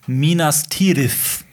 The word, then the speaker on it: Minas Tirith: Ist denn, ist denn, wir müssen doch auch, und das habe ich auch von dir gelernt, wir müssten eigentlich so Sachen sagen wie Minas Tirith. Ja, das so, TH ne? ähm, spricht man quasi wie das englische TH aus.